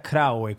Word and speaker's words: Graue: Creo que la pronunciación correcta sería Graue, como más K.